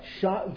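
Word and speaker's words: Sha: Shine. Give me the name? – Shine. – Shine. Like shine, but it's S-H-A, and it's spelled differently.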